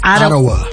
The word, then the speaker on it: Ottawa: Ottawa.